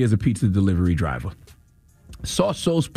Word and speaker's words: Tsotsos: Okay, his name is pronounced T-S-O-T-S-O-S. And according to the how to pronounce thing, when you Google it, it's pronounced Sossos.